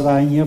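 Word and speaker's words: Bagna: O sea, nosotros, más que Baña, voy a hacer una corrección, Baña, vamos a usar Baña porque Gen en italiano es pronuncia Igne, para hacer una buena pronunciación, que no haya otros que dicen están hablando mal.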